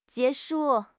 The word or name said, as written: jie2 shu4